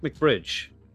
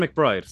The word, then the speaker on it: McBride: It is Kevin McBride, isn't it? Or is it McBridge? Oh, God. I'm a fake boxing fan, clearly. Kevin. Mick. Yeah, it's Kevin McBride.